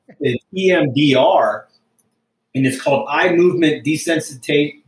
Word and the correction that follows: desensitization: Ah, desensitization and reprocessing.